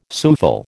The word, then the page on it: souffle: American English